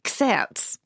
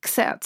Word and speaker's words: xertz: Xerz. – Oh, God. – I pronounce that with a kind of German accent. Xerz. So X-E-R-T-Z. – X-E-R-T-Z.